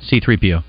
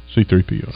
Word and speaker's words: C-3PO: I go with C-3PO. – C-3PO. – Mm-hmm. – Okay. Yeah. Get that? – Or is it CP-3O? – C-3PO. – C-3PO.